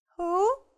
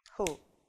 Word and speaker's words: who: И запоминаем слово who. Что в данном случае это у нас с вами сочетание будет читаться как who, кто, who.